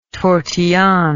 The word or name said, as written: tortillon